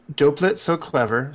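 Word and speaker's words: Doppelt so clever: Is it Doplet So Clever? Is that how I pronounce that? – Doplet So Clever. So Ganz Schön Clever means that's so clever. And Doplet So Clever means twice as clever.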